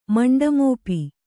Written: ಮಂಡಮೋಪಿ

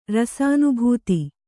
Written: ರಸಾನುಭೂತಿ

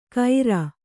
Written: ಖೈರ

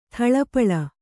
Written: ಥಳಪಳ

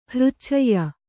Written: ಹೃಚ್ಛಯ್ಯ

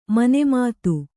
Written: ಮನುಮತ

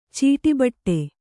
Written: ಚೀಟಿಬಟ್ಟೆ

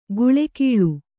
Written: ಗುಳೆಕೀಳು

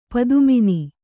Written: ಪದುಮಿನಿ

